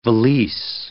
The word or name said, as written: valise